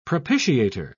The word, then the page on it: propitiator: British English